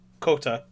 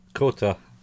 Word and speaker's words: Kohta: And then Coyote Man. – Kota. – Kota.